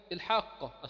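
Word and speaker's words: الحاقة: يبقى هنا هو الحاقه اصلها الحاقه، حاق سكون قه، ادي القاف الثانيه، اضغمت القاف الاولى في القاف الثانيه،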